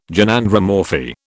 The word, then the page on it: Gynandromorphy: British English